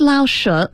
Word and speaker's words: Lao Shu: The second Lao Shu, make sure I'm pronouncing that correctly, Brian. Will you please correct my tones for me? – That was actually perfect.